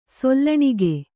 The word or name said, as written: ಸೊಲ್ಲಣಿಗೆ